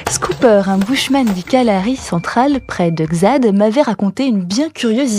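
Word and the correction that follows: Xade: Xadé. Ça s'écrit Xad, mais ça c'est Xadé.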